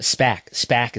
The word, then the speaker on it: SPAC: So moving on to some former WWE executives from Bloomberg, Berrios and Wilson, SBAC may acquire Bowlero. Bowlero Corp, a operator of bowling centers at SPAC is the proper SPAC pronunciation.